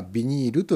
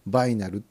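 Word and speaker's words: vinyl: ビニールという発音ではなくてバイナルっていうのがアメリカアメリカじゃないな英語の、まあ、正確な発音らしくて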